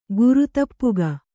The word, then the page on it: ಗುರುತಪ್ಪುಗ: Kannada